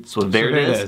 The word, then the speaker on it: Suaverdez: Suaverdez. – Suaverdez. Yeah. You gotta get the emphasis on the right syllable, right?